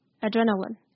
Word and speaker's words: Adrenaline: L-I-N-E line 但是两个字发音不一样哦所以要注意 Deadline Deadline 是 line Adrenaline 是 lin, n, lin Adrenaline Deadline 好大家可以念念看再来我知道大家在想什么 这句话最后一个字要怎么念嘞？